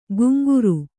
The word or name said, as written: ಗುಂಗುರು